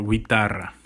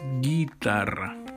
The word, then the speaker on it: guitarra: So, like in guitar, we don't pronounce the letter U. In Spanish, we wouldn't say guitar or guitarra. No, we omit the letter U there and we pronounce guitarra, because it's written or spelled with the G, U, I.